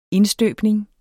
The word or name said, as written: indstøbning